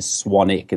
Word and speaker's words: Swanwick: Yeah, my last name is spelled Swanwick. Right. So it's Swanwick. But the correct pronunciation is Swanick. It's the silent W. Just, you know, like Warwick. The name Warwick, it's Warwick.